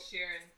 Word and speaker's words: Sheeran: Is it Sheeran? – Sheeran. – Finally pronounced Ed Sheeran.